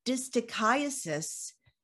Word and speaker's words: distichiasis: Now, I'd never heard of this term before, and if I butcher it, you fix it for me. Lymphedema distichiasis syndrome. Is that correct? – That is correct.